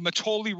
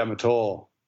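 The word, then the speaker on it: Mattole: And I think a lot of that is what's called the Matoli River watershed. – Yeah, Matol. – Matol. Is that how you pronounce it? Okay. Yeah. I was pronouncing Matoli.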